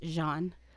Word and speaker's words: Gean: So this guy named Jean Lefray, spelled G-E-A-N, but pronounced Jean.